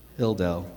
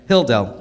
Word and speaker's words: Hilldale: let me say it correctly, because I always put an extra S, I think, in it. Hilldale, no S, right? Hilldale, okay?